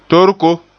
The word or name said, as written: torko